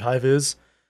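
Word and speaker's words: High Vis: High Viz. How do you spell that? – H-I-G-H. And then V-I-S. Okay. I think it's Viz as in high visibility. – Okay. – They're named High Viz.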